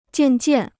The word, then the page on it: 渐渐: jiàn jiàn